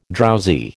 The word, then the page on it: drowsy: American English